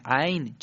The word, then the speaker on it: عین: خب تلفظ صحیح عین چجوریه؟ بله اینم خیلی آسانه، مشکل خاصی نداره. شما عین رو باید مقداری غلیظ از گلو ادا کنید. غلیظ از گلو به این صورت: ع ع ع او.